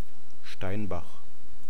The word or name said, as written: Steinbach